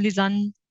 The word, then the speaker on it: Lisann: I'm Lisann.